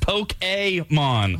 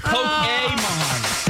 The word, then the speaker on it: Pokemon: Meanwhile, all of this talk is upsetting some nerds in the audience, like Trevor, who wrote in that says, you're saying Pokemon wrong. It's actually pronounced Poke-A-mon. – Whatever. – So it's Pokemon. Sorry.